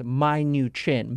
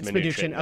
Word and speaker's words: Mnuchin: I think about it as when we first heard of him, when he first nominated, we pronounce it as my new chin, Mnuchin. – Oh, that's probably right. – I just remember when we first learned of him, Bill, we spent like a whole week with Bill trying to explain to him how to say it. So I know it's Mnuchin.